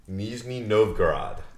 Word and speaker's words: Nizhny Novgorod: How would you pronounce that? That two in? – A classic story of Joe's with unpronounceable cities. Right? You always do this. – Yeah, I would say Nizhny. Nizhny Novgorod.